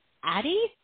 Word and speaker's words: Adi: So, again, it's Adi? – Adi.